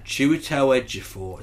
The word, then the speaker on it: Chiwetel Ejiofor: Chiwetel Ejiofor. Is that how you say his name? – Yes, it is.